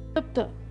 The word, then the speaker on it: क्लुप्त: i will check with the correct pronunciation one minute hold on klupta klupta klupta